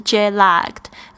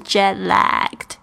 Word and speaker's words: jet lagged: 我们特别要注意到这个 jet lagged，这个 a 的发音呢是这个蝴蝶的这一个音标哦，所以你的舌头要稍微向向下压一点，不是 jet lagged，而是 jet lagged。